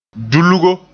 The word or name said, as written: dulgo